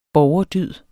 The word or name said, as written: borgerdyd